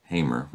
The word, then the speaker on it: Hamor: Hamor, or Hamer. Hamer is how it's pronounced, the father of Shechem.